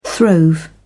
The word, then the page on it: throve: British English